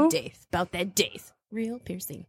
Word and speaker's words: Daith: It's called the Daith. Piercing. I just needed to know. – Spell it. – D-A-I-T-H. – Really? – Daith.